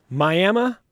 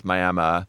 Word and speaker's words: Miami: Yeah, it's spelled Miami, pronounced Miami, and it's a small town in the panhandle of Texas.